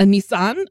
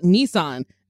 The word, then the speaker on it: Nissan: A Nissan. And I like looked at the pronunciation. They're like, in Japan, they call it Nissan.